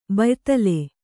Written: ಬ್ಯಾದಳ

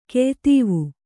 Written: ಕೆಯ್ತೀವು

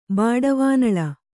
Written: ಬಾಡವಾನಳ

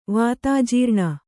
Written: ವಾತಾಜೀರ್ಣ

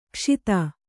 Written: ಕುಶದ

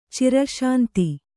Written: ಚಿರಶಾಂತಿ

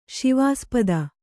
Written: ಶಿವಾಸ್ಪದ

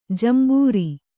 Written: ಜಂಬೂರಿ

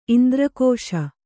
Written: ಇಂದ್ರಕೋಶ

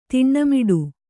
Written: ತಣ್ಣೆಮಡೆ

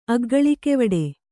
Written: ಅಗ್ಗಳಿಕೆವಡೆ

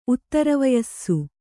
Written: ಉತ್ತರವಯಸ್ಸು

